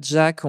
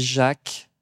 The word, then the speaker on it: Jack: Alors, on ne dit pas Jack, on dit Jacques Aubert. J'y tiens beaucoup, même si ça s'écrit à l'américaine.